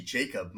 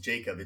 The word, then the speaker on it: Jakeb: It's actually Jakeb. My first name is Jakeb. It just looks like Jake B because of the way it's spelled.